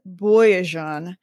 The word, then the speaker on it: Boyajian: And so there's one particular star, and I looked up how to pronounce it. it she says Boyajian uh, okay.